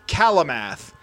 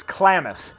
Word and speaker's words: Klamath: I had to look it up, and I knew I was going to say it wrong, because I would read it as Calamath, but I'm also probably spelling it wrong. It's Klamath, and he hails all the way from Klamath, which is in the southern part of Oregon.